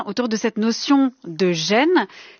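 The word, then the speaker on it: Gêne (仁): qui s'écrit REN mais que l'on prononce gêne, je le prononce bien Rémi Mathieu ? – Tout à fait. – Et qui est un... – Au deuxième ton, ça serait encore mieux. – Dites-le. – Gêne. La voix monte. Gêne.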